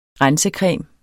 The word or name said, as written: rensecreme